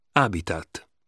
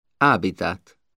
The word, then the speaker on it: habitat: The letter H is not pronounced. Anno. Habitat. – Anno. – Habitat.